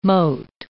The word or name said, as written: moat